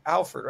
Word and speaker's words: Alford: Alford, right? – Yep.